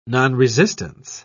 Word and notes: nonresistance: British English

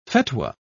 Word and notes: fetwa: British English

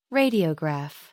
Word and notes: radiograph: American English

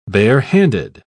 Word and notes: barehanded: British English

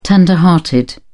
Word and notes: tender hearted: British English

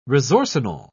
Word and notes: resorcinol: British English

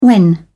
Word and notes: when: British English

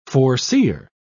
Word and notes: foreseer: British English

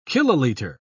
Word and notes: kiloliter: British English

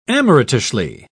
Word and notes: amateurishly: British English

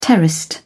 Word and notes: terraced: British English

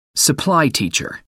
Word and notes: supply teacher: American English

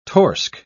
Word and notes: torsk: British English

